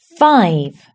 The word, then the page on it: five: British English